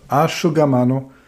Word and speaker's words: Asciugamano: Wenn du dir jetzt die PDF-Datei anguckst, dann siehst du bei Handtuch Asciugamano und bei Aufzug Ascensore das SC im Italienischen vor I und E wie ein Sch ausgesprochen wird. Also Asciugamano, Ascensore.